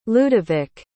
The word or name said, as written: ludovic